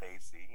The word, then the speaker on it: Desi: Just the pronunciation is Desi. You know, it's it means, from what my dad told me, it means like Desi's like a man of the land, of the South Asian land. It's a Desi, you know. And I think the pronunciation is very simple. It's like they, saying the words they see, like they see this coming. They see, that's it. They see Hit Squad.